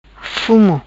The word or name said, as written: Fumo